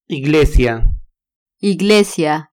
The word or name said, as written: iglesia